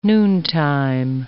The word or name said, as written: noontime